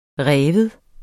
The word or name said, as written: rive